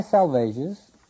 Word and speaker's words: Salvages: He says, the dry salvages, presumably Les Trois Sauvages, is a small group of rocks with a beacon off the northeast coast of Cape Ann, Massachusetts. Salvages is pronounced to rhyme with assuages.